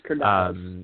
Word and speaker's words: Curdiles: I don't know what the right pronunciation is, but I think it's Curdiles, like crocodile.